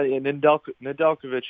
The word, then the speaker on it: Nedeljkovic: Did I pronounce it correctly? Because I've been butchering this for the last week. – Yeah, Nedeljkovic is good, or if you want to just say Ned, Ned's fine, too.